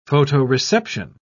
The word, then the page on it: photoreception: British English